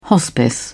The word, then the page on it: hospice: British English